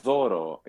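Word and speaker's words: doro (δώρο): The gift actually is to doro, is the gift.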